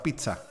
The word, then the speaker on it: pizza: Acá se trató el tema de los que dicen mal la palabra pizza. Viste que se escribe P-I-Z-Z-A y la correcta pronunciación es con T. Pizza. Así se pronuncia.